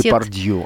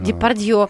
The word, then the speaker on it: Депардье: Депардье. – Она Депардье правильно да, произносит.